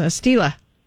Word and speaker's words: Stila: It is Stila.